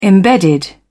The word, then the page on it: embedded: British English